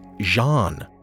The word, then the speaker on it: Zan: Moreover, their native word for majesty or king was Zan, Z A N, pronounced much like the French would pronounce the name Jean.